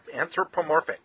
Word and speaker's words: anthropomorphic: Animals, human, anthropomorphic? – Yep, anthropomorphic.